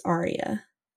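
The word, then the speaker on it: Aurea: It's A-U-R-E-A. – Oh, that's weird. – Yeah, it's like Aria, Aria. – And that's weird. – But it's pronounced Aria.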